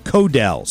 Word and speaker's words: Codel: My theory is Carissa Cordell. Is that did I say the name right? Carissa Codell, C-O-D-E-L, is going to, her career is going to blow up over this.